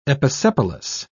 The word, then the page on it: episepalous: British English